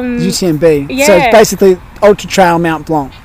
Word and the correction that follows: Mont Blanc: Mont Blanc.